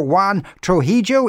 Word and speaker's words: Juan Trujillo: Dr. Juan Trujillo is a consultant paediatric allergist in Cork University Hospital.